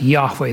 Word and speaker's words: Yahweh: Well, in Hebrew, we would say Yahweh. We would pronounce it Yahweh. That's how you would say it.